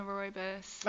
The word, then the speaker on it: rooibos: I like, what, I don't know how to say it, rooibos? – I love that. – Love me a rooibos.